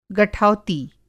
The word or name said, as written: गठौती